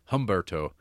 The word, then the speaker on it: Humberto: but with an H. Humberto. The H is silent.